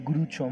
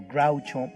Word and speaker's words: Groucho: De hecho, en inglés no se dice Grucho Marx, sino Groucho Marx.